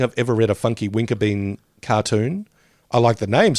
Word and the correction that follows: Winkerbean: Oh, Funky Winterbean or Winkerbean? – It's Winkerbean, but I had no idea who it was.